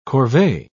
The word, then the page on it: corvee: British English